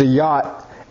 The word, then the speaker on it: jot: In the original language, a jot was, it's pronounced a yacht, and it was the least stroke of a pin.